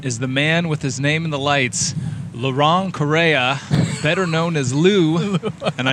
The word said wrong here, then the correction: Laurent: So, that was wrong. It's actually Laurent. – Laurent. – Laurent. Yeah, it's spelled L-A-U-R-E-N-T.